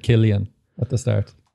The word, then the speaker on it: Cillian: But it's always pronounced Killian if it's Irish. – Yeah. Yeah. – Well, I don't see it anywhere else with that name. – It's just the C. – Aye. – Throws everyone.